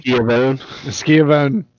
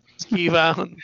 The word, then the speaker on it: Schiavone: Schiavone. – Schiavone. – That's how he's, because if you look at it phonetically, that's how it looks like it should be.